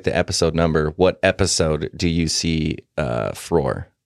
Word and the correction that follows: Throg: Throg, you mean? – Yeah, sorry.